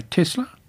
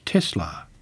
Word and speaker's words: Tesla: With the name taken from the Croatian-born Serbian-American inventor Nikolai Tesla, purists argue that the unvoiced S is the correct way to say it. This should mean pronouncing the company name as Tesla, whereas Tesla's CEO, Elon Musk, prefers to say Tesla instead.